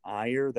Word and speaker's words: Iyer: The name is Raj, R-A-J. The last name is Iyer, that's spelled I-Y-E-R.